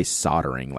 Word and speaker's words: soldering: In the US, we say soldering, like there's no soldering, because people make fun of me because it's the way that I say solder.